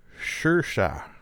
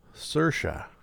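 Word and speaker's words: Saoirse: How do you pronounce a name? Sersha. Sersha. I would not have guessed that.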